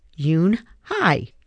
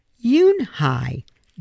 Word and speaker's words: Yoon-hee: "Yes, it's pronounced Yoon-hee. And it means grace," Mr. Kim added. "Yoon-hee," Joey said slowly, and this time perfectly.